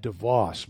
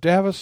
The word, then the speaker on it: Davos: Okay, Davos, Switzerland. I have said DeVos most of my life because I grew up around Grand Rapids, and Van Andel and DeVos were the two founders of Amway.